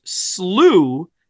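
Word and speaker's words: slough: They do note that it is typically pronounced slew in most of the United States.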